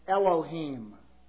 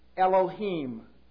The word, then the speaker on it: Elohim: First of all, there is the name Elohim. Rhymes with team. Elohim.